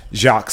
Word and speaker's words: Jacques: I am Jacques Slade. It's spelled J-A-C-Q-U-E-S, by the way, not J-O-C-K.